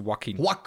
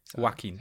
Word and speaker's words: Joaquin: Nee, das ist Joaquin. Joaquin. – Der Asiate Joaquin Phoenix. – Joaquin. Das ist ja. – Was macht das J dann in seinem Namen? – Das ist Wa.